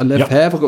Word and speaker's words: Lefebvre: Ähm, kennen wir noch von, du hast vorgestellt, äh, Lefebvre, äh, Lefebvre ja. und Murphy. – Sehr also schönes Serie. Bitte korrigiere mich mit der Aussprache, korrekt. wenn es falsch ist. – Fürs französische Teil war es korrekt.